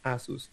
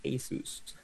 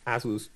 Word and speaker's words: Asus: Em, ellos inclusive sacaron un comercial que decía cómo pronunciar correctamente el nombre de la empresa, ¿verdad? Que no es Asus, sino que es Asus. y nunca me gustó yo, para mí es Asus, punto.